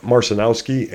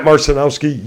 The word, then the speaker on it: Marcinowski: Matt Marcinowski, and Matt, I apologize if I said that incorrectly. – You said it correctly, actually. – Okay. Matt Marcinowski, you got to follow him on TikTok, Instagram, and in life.